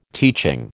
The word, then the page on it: teaching: American English